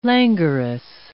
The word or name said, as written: languorous